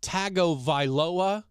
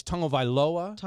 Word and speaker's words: Tagovailoa: People mispronounce it Tagovailoa, mm-hmm. – but there's a tongue in there. So the T-A-G makes a tongue sound. Mm-hmm. That's Tagovailoa. – Tagovailoa.